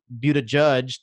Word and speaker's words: Buttigieg: like Seth Moulton and Pete Buttigieg. That's how you say his name.